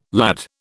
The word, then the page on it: lad: British English